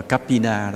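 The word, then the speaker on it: Kappina: Đây là một trường hợp rất kỳ lạ, đó là tôn giả Maha Kiếp Tân Na, gọi là tiếng Ấn Độ ta đọc là Kapina đó.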